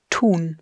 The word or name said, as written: Tun